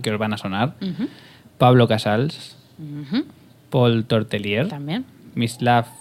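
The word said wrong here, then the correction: Mstislav: no, ¿cómo se dice? – Mitislav. Mistislav.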